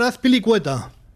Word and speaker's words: Azpilicueta: Disfruta del fútbol con ese gol de Azpilicueta, uno de los nombres que hicieron famoso a Acrobeto. – ¿Ah? – Así se pronuncia, sí. César Azpilicueta.